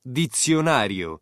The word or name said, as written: dizionario